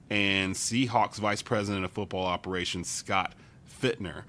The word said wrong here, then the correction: Fitterer: uh, Fitterer, excuse me, I pronounced that uh, wrong.